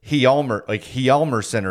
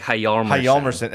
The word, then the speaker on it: Hjalmarsson: When we were talking to Hossa the other day, he said, we've all said Nicholas Hjalmarsson forever, but he said Hjalmarsson or something. – It was like Hjalmarsson. – And I'm sure he is saying it correctly.